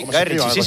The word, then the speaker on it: Guy Ritchie: Lo he dicho bien. Guy Ritchie. – Sí, Guy Ritchie.